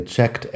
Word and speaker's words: checked: La pronunciación de los verbos regulares después de un sonido como K, tenemos la ED que suena a T. Checked. Kt, así tal cual.